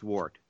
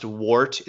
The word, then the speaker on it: wort: It's not pronounced wart. It's pronounced wort. – Wart? – Go ahead. Wort, not wart. Not like a wart on your hand. It's a wort, like a plant.